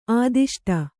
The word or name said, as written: ಆದಿಷ್ಟ